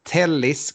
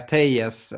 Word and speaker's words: Telles: Mest intressant kanske är att Alex Telles kommer in, som jag har förstått att ska uttalas. Man lockas att säga Telles eftersom att det är dubbel elde. Man är ju portugis eller brasse.